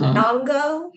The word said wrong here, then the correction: Viungo: Viungo. Viungo. Oh, I was so close. Almost, yeah. I was so close. Viungo.